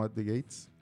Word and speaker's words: At the Gates: Sí, magistral, bueno, si se quieren comprar una guitarrita muy rica y tocar como At the Gates, ahí lo dije bien.